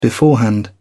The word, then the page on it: beforehand: British English